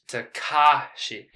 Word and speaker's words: Takashi: Yeah, I'm a stickler for pronunciations. Takashi. You gotta put the emphasis on the ka.